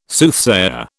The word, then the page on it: Soothsayer: British English